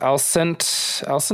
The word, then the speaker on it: Alicent: I believe it's Alicent. I believe I'm saying that right.